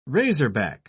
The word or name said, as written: razorback